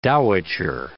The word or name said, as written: dowitcher